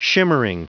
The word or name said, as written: shimmering